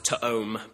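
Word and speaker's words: to-om (תם): And so the word tom in the Hebrew, I guess it would have been pronounced to-om, but it's literally tom.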